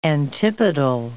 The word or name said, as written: antipodal